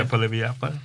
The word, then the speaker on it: Poilievre: Yeah, Poilievre. – Poilievre.